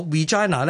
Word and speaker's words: Regina: 系啦，我惊尴尬读错咗咧，所以我就跟翻香港人所读 Regina。咁拉丁文亦都 Regina 啊嘛。不过如果大家要跟足英国人嘅传统发音咧，读 Regina 咧，Elizabeth Regina the Second。